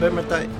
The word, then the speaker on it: Vermintide: nos venía a hablar de Warhammer Vermintide.